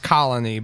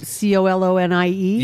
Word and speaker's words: Colonie: I hope it's pronounced colony, but it's not spelled like colony. – Is it C O L O N I E? – Yeah. – Colonie. – Colony. – Oui, c'est comme ça.